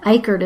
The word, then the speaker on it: Icard: So it's M-I-C-H-E-L-L-E. And then Icard is spelled I-C-A-R-D.